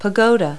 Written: pagoda

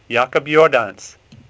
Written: Jordaens

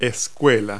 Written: school

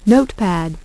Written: Note Pad

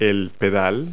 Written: pedal

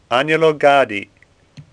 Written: Gaddi

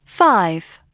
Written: five

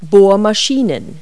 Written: drills

